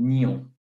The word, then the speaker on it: Nyon: It's called Nyon. That's how you say it in French. N-Y-O-N.